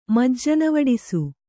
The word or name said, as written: ಮಜ್ಜನವಡಿಸು